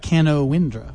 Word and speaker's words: Canowindra: And I looked it up, and it's spelled C-A-N-O-W-I-N-D-R-A. Looks like Canowindra, but it's pronounced Canowindra, Canowindra.